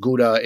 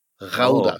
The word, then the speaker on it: Gouda: Do you know how it's actually pronounced? – I don't know. – It's something very similar to Gouda. Gouda. Oh, so it's an ow in the beginning, and the G, you know, it's something that doesn't exist in the English language.